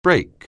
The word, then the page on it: brake: anglais